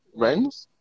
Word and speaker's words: Rennes: How's that pronounced? Rennes. Okay, great.